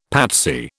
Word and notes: patsy: British English

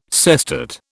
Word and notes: sestet: British English

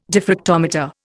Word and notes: diffractometer: Indian

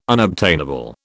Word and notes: unobtainable: British English

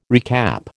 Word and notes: recap: American English

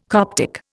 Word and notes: Coptic: Indian

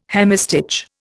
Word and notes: hemistich: Indian

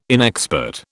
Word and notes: inexpert: British English

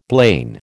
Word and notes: blain: American English

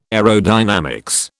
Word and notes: aerodynamics: British English

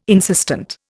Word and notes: insistent: Indian

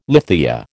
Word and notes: lithia: American English

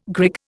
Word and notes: grig: Indian

